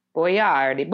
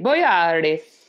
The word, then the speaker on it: Boyardee: That is not how you say his name. His name is pronounced Boyardi. Boyardi.